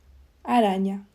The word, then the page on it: arana: Spain